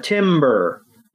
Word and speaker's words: Tamburr: And it throws out a Timber. – Yes, a Timber. – If you're keeping track at home, that is spelled T-A-M-B-U-R-R. Tim, basically, Tim is cold. – Yes. Basically, think of a tree falling.